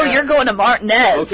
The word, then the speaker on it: Martinez: All right, there's a small town in Georgia outside of Augusta, and it is spelled M-A-R-T-I-N-E-Z. How would you pronounce that? – Martinez? – Yeah, right? – Martinez. – Yep, it's Martinez.